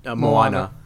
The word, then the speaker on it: Moana: Moana.